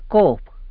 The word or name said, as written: cob